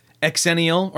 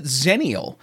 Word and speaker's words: Xennial: But they've come up with Xennial, or maybe we pronounce it Zennial, in that, you know, that's how you like xylophone.